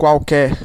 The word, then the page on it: some: /sʌm/